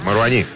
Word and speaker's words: Маруани: Маруани. – Маруани. Он француз, но последний слог ударения.